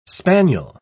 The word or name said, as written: spaniel